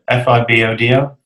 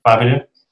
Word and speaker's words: Fibodo: Fiverr.do, whichever it's fine. Yeah, it stands for Find Book Do, Fibodo, Fiverr.do.